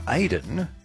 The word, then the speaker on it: Aodhan: Now, his name is spelt A-I-D-A-N, but the band is spelt A-O-D-H-A-N, but pronounced Aidan in that traditional style.